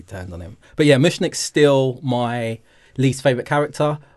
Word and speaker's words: Mushnik: To be fair, I think I was on Mushnik's side. Is that how I pronounce it? – Yeah.